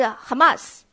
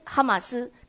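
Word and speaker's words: Hamas (חמס): 地上满了 Hamas，地上满了强暴，满了 Hamas。为什么地上满了 Hamas 呢？因为我们不管你听过几次，我今天再讲一次。那么强暴的希伯来文是 Hamas，它的发音哈马斯，跟现在的伊斯兰极端恐怖主义哈马斯是同音也同义。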